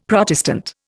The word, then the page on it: Protestant: Indian